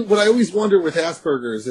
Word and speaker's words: Asperger's: It's pronounced Asperger's, right? Asperger's syndrome.